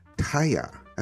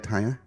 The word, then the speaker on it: Tahja: Today, our guest is Katie Taya. That's how I pronounce it, right? Hiya, Taya? – Yes.